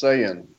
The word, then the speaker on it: Saiyan: It's pronounced Saiyan.